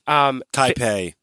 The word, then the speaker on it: Taipei: Am I saying it wrong? I'm saying it wrong. But Taipei. – There you go.